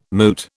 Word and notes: moot: British English

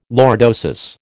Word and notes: lordosis: American English

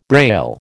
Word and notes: Braille: American English

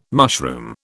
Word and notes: mushroom: British English

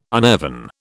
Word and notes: uneven: British English

